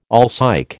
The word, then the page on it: alsike: American English